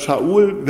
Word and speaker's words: Shaul: Und wie viel Hoffnung in ihn reingelegt war, das ist auch Shaul, als Shaul wird das ausgesprochen, war eigentlich der Name des ersten Königs Israels gewesen.